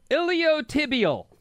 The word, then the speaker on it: iliotibial: Over Bonnie, the IT band is pronounced iliotibial.